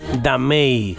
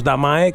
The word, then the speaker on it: Damae: Los responsables de esta producción son Alfie Cridland, Amaru Klein y Damei. ¿vale? Que es como se pronuncia correctamente. Nosotros decimos Damae, que es como lo pronunciamos en español, pero en su saludo lo escucharás.